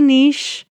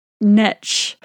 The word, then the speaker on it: niche: I say niche, but I've also heard it niche.